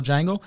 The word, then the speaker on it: Django: And the guy at the bar with Jamie Foxx, he says, it's pronounced with a J. And he goes, I know, because that's the original Django.